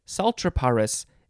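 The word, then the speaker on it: Psaltriparus: Did I say it correctly? I think I did. But I think the P is silent. Saltriparis minimus.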